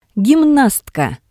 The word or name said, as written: gimnastka